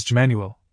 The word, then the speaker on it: Jmanuel: It is quite all right for the beginning of truth, but you must know that my name was Jmanuel, pronounced as an I, as in Emmanuel.